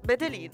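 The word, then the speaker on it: Medellín: Allora, il brano si intitola Med- Medellin. Med- si legge? – Come hai detto tu adesso, Bedelin. Bedelin. – Quella... Medelin, con la M.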